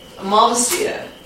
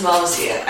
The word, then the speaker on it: Malvasia: You pronounce it as Malvasia. I've heard other people pronounce it Malvasia. – And then there's also the Central Italian pronunciation of Malvasia. – So they're all acceptable pronunciations.